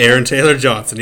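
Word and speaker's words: Aaron Taylor-Johnson: Aaron Taylor Johnson, you got it.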